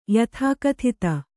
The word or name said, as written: ಯಥಾಕಥಿತ